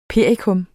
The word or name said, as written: Hypericum